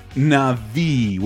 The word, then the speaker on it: Na'vi: It'll scan your body and face and turn you into a Na'vi, which is the proper pronunciation of this thing no one remembers.